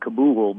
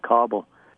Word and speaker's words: Kabul: I noticed a lot of Americans pronounce it Kabul, but the locals seem to call it Kabul.